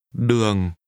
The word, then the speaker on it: đường: Đường. Đường. Đường. – Road is pronounced with a low falling tone.